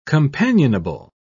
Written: companionable

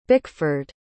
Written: bickford

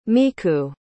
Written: miku

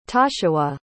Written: toshawah

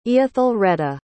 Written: eathellreda

